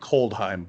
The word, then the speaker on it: Kaldheim: The first set of the year is Kaldheim. I think that's how you pronounce it. I've heard Coldheim, but I know it's either way.